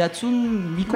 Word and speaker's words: Hatsune Miku: Hatsune Miku.